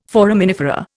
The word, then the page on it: foraminifera: Indian